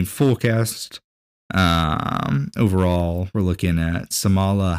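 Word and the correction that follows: Salma: You mean Selma? – Selma, yes. Selma. Did you say Samala?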